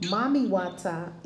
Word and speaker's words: Mami Wata: And in Vodun, Mami Wata is the great mother of the universe. – Oh, wow. – Uh-huh. It's pronounced, it's Mami, M-A-M-I-W-A-T-A. – Yep, W-A-T-A, yeah.